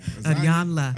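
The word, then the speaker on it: Iyanla: Is that how you say her name? Isaiah. – Yeah, whatever her name. – Ayanla. – Ayanla. Ayanla. – Ayanla Van Zandt.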